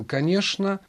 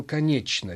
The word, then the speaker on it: конечно: Но, во всяком случае, этим обычно объясняют то, что москвич говорил «конечно», а петербуржец говорил «конечно». Ведь написана-то буква «ч», значит, и читать нужно «конечно», «что», «чтобы», «почтовый», в то время как на Москве говорили «что», «чтобы» и даже «поштовый».